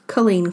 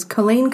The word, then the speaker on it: Culzean: Now, I might slip up through this episode, because Culzean Castle is spelt C-U-L-Z-E-A-N. – I remember it from the master list now. – Yes, so it's not spelt Culzean, and I'm reading my notes, so, you know, it might slip through the net, and I just have to apologise in advance.